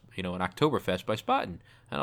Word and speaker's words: Spaten: And also, I remember that I pronounced Spaten wrong. – What did you, I was actually not going to say anything, but yeah, it's Spaten.